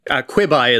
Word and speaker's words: Quibi: Quibi is what I've insisted on calling it because it's quick bites.